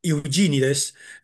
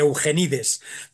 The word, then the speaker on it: Eugenides: cuyo nombre habrá que pronunciar a la inglesa, a la norteamericana, Jeffrey Eugenides. A mí me sale llamarle Eugenides,